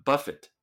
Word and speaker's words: buffet: 那 buffet 是自助餐，那个是法文。那 buffet 这个是英文哈，它 t 要发音，那重音在前面。